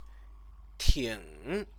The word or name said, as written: ting